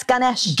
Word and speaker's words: Ganesh: Ganesh. – What is it? – That's Ganesh. – Ganesh. I'm glad you pronounced it so I didn't have to.